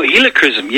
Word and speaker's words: helichrysum: And I'd just gotten in some of the helicerium. Helicerium? Is that how you say it? – Oh, helichrysum. Yes, sir. Helichrysum.